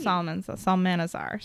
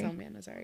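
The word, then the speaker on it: Psalmanazar: And mine was George Salamanzar. – The silent P. – Salmanazar, sorry. – Salmanazar, yeah.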